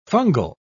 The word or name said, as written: fungal